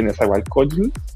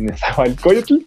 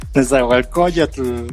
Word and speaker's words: Nezahualcóyotl: en la zona de Nezahualcóyotl, de Nezahualcóyotl, perdón. – Nezahualcóyotl.